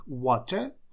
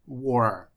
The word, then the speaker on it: water: Британцы говорят water, то есть букву T обязательно произносят. Американцы ее теряют и говорят war.